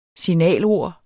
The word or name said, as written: signalord